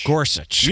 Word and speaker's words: Gorsuch: I believe I'm pronouncing this correctly. Goalie Trevor Gorsuch. – Gorsuch. – Yep. Kind of like the Supreme Court Justice.